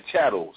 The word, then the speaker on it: chattel: Some of my ancestors were indentured servants, and the others were enslaved in the chattel slavery system. – Cattle? They made cattle be slaves, too? – No, no, not cattle, Jabril. All of your children repeat after me. It's spelled C-H-A-T-T-E-L.